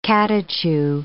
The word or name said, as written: catechu